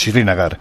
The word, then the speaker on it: Srinagar: Es que la pronunciación de la capital es Srinagar. Muy difícil, es Srinagar, sí.